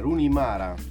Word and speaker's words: Rooney Mara: Attenzione, Rooney Mara. – Complimenti. – Che tutti ricordiamo. – Complimenti, bella pronuncia. – Sì, cazzo, Rooney Mara, sì.